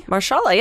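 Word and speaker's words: Mahershala: So now, the reason I told you about this story is that in the Netflix series, I'm going to mispronounce his name, Marshala Ali? – Marshala, yeah.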